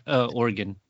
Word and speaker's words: Oregon: Did I say it right, by the way? – Oregon. – Oregon. – Yeah, we actually have a bumper stickers that is spelled Orygun, Oregon. Yeah, for that very reason.